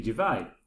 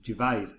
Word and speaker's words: Gervais: You pronounce it with a silent S. However, it's not a silent S. It's pronounced... I have listened to all of Ricky Gervais' programmed podcasts and his old XFM shows, and as well as his stand-up DVDs, and he always pronounces his name as it's spelled. There was even a bit of discussion about it on the Graham Norton show, as Louis Walsh pronounced his name the way you guys do, and they were laughing about it.